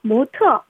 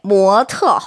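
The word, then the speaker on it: 모트 (模特): 모트, 모델입니다. 본뜨 모자예요. 특별한 특자로요. 모는 이성이고 트는 사성입니다. 모트. – 네, 모트 혹은 모털이라고 발음할 수 있죠.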